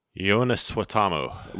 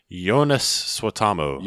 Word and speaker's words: Suotamo: How do you pronounce his name, dude? New Chewbacca. – Jonas Suotamo. – One more time. – Jonas Suotamo.